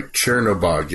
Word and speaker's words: Chernobog: Uh, we uh, we see a scene of Wednesday's hammer, uh, Chernobog, Am I saying that right? Chernobog, yes. Okay.